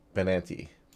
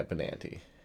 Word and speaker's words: Benanti: Oh, uh, first, uh, Allison Becker, Lori, Laura Benet, Benetani. Yeah, Benanti, Benanti. – Benanti. Yeah, Benanti. That's how you pronounce that.